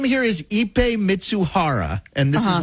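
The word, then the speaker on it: Ippei Mizuhara: There's a phonetic pronunciation, Ipe Mitsuhara.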